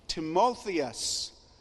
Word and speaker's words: Timotheus: Now the name Timothy, pronounced in the New Testament Greek, is Timotheus, which means both honored by God and honoring to God.